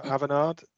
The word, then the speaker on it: Avanade: I work for Avanade. That's how you say it, Matt, because you didn't earlier.